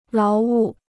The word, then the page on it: 劳务: láo wù